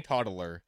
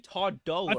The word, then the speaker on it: toddler: It's two syllables. – Toddler. – He's saying three.